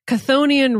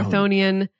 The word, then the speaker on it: Chthonian: So I learned it's actually pronounced Chthonian. The CH is silent. – What? – Thonic, or Thonian. And the simplest way I can explain it is that Thonic is sort of the opposite of Olympic.